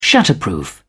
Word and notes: shatterproof: British English

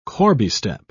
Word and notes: corbiestep: British English

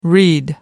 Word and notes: reed: American English

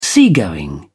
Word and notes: seagoing: British English

